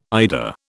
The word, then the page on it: IDA: British English